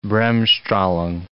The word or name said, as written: bremsstrahlung